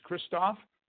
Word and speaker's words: Christoff: My first name is called Christoff, C-H-R-I-S-T-O-F-F, like Frank.